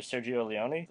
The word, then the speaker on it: Sergio Leone: It was directed by Sergio Leone, that's saying the name right.